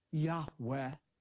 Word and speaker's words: Yahweh: whom Semitic scholars believe is pronounced a breathy, reverent Yahweh.